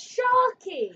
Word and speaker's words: Sharkey: It's Sharkey!